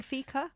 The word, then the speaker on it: fika: So, what better way to take a break from the stress of modern life, clubbers, than by engaging in fika? Is that how you say it? You're doing fika? – Yes.